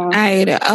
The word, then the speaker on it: Aida: I think it was that show called, was it Aida? Is that how you say it? – She was in Aida. – Aida. Okay.